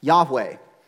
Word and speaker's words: Yahweh: which is most commonly pronounced as Yahweh.